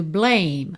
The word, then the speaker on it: blame: We've only got each other to blame. Mais um rarara ali. Got each other. To blame. Termina só na letra M. Esse E final não é pronunciado.